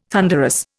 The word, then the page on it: thunderous: Indian